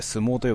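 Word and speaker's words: 角力: 確力と書いて相撲と読むの、これ一応意味は相撲らしいですよ。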